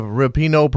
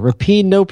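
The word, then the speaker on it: Rapinoe: I have Rapino problem back here. You can't, it's right there. – I think you put the stress on the wrong syllable there. Rapino problem.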